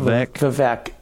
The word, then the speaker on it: Vivek: Vivek. I had a friend named Vivek and Vivek Ramaswamy.